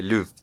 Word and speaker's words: Louvre: De Louvre.